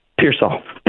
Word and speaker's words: Pearsall: I'm a little excited about, well, not say excited, but I have good feelings about Ricky Parcell, or Parcell, or however you pronounce his last name. – Parcell. – What was it? – Pearsall.